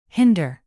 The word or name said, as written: hinder